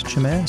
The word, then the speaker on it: Chimere: I'm pretty sure that's how it's pronounced, Chimere. C-H-I-M-E-R-E, Chimere.